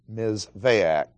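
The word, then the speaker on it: Mizvaak (מזבח): The Hebrew word is misveach, M-I-Z-V-A-A-C-H. Pronounced Mizvaak. Mizvaak does not mean altar.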